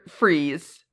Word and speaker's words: Fries: They give him the name Victor Freeze, but Freeze F-R-I-E-S. – My dumbass has been pronouncing it fries in my head.